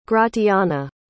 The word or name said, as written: gratiana